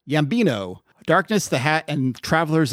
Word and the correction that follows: Yambio: Actually, I think I said that wrong. It's Yambio. Yeah, there we go. Yambio, not Yambino.